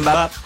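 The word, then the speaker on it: Mbappé: Mbappe.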